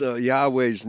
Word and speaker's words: Yahweh: And we're pretty much in agreement that Yahweh is the most consistently approved by all scholars, although sometimes you have Yahowah, a three-syllable word, as opposed to the two-syllable word.